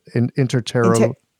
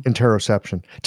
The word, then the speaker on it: interoception: Interoception. – Interoception.